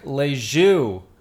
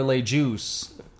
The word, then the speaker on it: Le Jus: Ladies and gentlemen, we are going to drink Le Jus, or Le Juice. – Yes. It's really, it depends on, I guess, where you are in America, if you want to mispronounce it, by our friends over at Alarmist Brewing.